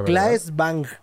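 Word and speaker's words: Claes Bang: Kais Blanc, Sí, Blanc. Esperen, es que no lo sé pronunciar. No, es Claes, Bang.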